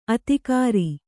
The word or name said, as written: ಆದಿಗಿರಿ